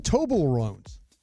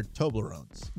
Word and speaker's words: Toblerones: What are the Toblerones? I didn't say it right. – They're Toblerones.